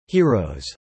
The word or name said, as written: heroes